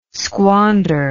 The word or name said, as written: squander